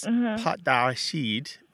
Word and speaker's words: pâte: Because what you're saying in French is pâte d'arachide, which means peanut paste. And it's spelled P-A-T-E. and there's a circumflex, a little hat on the A. And in French words, that means it used to be spelled with an S. So it used to be spelled P-A-S-T, but now it's spelled P-A-T-E. But you translated the word P-A-T-T-E, which means paw or leg of an animal. – Yes. – So you translated, so you, it, they sound almost the same, pot and pat.